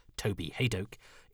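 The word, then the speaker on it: Toby Hadoke: my name is often pronounced incorrectly, so that this, Toby Haydoke, is how it is done, and please could you make sure it's like that on the credits.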